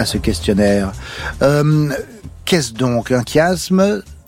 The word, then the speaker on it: chiasme: C'est que ça se prononce bien chiasme. J'ai eu la confirmation de la part de Zoé qui a écrit le questionnaire.